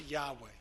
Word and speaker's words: Yahweh: Nearly all scholars agree that the name is pronounced Yahweh.